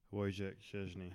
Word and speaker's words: Wojciech Szczęsny: Wojciech Szczęsny.